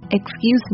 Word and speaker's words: excuse: 注意呢 excuse me，那这个 c 是浊化的， excuse me， excuse me。